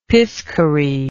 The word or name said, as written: piscary